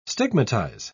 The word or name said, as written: stigmatize